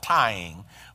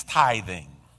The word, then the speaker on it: tithing: Some people mispronounce it and call it tying, but it's not tying, it's tithing.